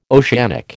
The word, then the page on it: oceanic: American English